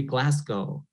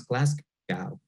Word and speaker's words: Glasgow: That's the city Glasgow, which a lot of people pronounce Glasgow.